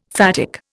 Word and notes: phatic: Indian

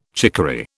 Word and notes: chicory: British English

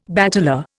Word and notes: bateleur: Indian

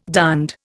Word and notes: darned: Indian

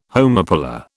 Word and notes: homopolar: British English

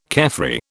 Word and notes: carefree: British English

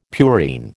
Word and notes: purine: American English

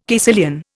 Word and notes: caecilian: Indian

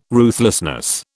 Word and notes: ruthlessness: British English

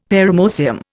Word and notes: paramoecium: Indian

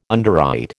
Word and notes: underwrite: American English

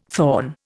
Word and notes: thorn: Indian

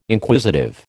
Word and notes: Inquisitive: American English

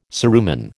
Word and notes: cerumen: American English

